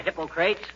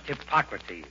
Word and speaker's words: Hippocrates: Hi, Hippocrates. If you are referring to the father of medicine, McGee, it's pronounced Hippocrates.